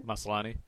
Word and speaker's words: Maslany: Maslany.